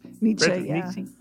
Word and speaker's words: Nietzsche: Evidently, I was smarter than I thought I was because I just read that Nietzsche, is that how you say it, Nietzsche? – Nietzsche.